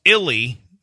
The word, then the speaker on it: Illy: Pronounce Illy to rhyme with Billy.